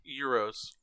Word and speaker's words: gyros: Is it gyros? Is that how you pronounce it? – Euros.